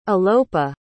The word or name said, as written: alopa